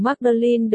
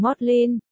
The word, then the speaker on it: Magdalen: Tôi biết trường cao đẳng Magdalen được phát âm là cao đẳng Modlin.